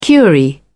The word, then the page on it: curie: British English